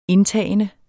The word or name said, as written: indtagende